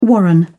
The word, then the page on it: warren: British English